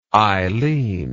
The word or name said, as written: eileen